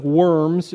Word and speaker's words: Worms: And this took place in the city of Worms, Germany, which in our spelling looks like worms. In German, it would be pronounced Worms.